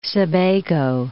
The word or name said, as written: Sebago Lake